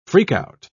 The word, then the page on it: freak-out: British English